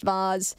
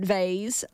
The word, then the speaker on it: Vaz: I think it's pronounced Vaz. I'm pretty sure it's pronounced Vaz if you say it Vase.